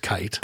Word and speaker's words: Keith: his last name's spelled Keith, what we would say Keith, but pronounced Kite.